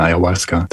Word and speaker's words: Ayahuasca: Was ist das Ayahuasca und habe ich es richtig ausgesprochen? – Ja, ist richtig ausgesprochen, Ayahuasca.